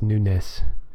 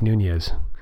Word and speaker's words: Nunes: Just talking like that because he wants his name to be pronounced Nunes. What's wrong with that dude? Your name's Nunez.